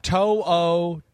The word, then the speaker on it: To'o: To'o. – Yeah, Henry To'o. – To'o. Okay. I know. Yes. It's one of those that's like, it actually reads exactly the way it's spelled.